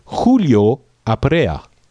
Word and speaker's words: Julio Aprea: First of all, let's go with the order of Skype Julio Aprea.